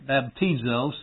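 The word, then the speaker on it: baptizo (βαπτίζω): All right, baptizo. So this is a transliteration of the Greek and the phonetic pronunciation DZ, but anyway, baptizo.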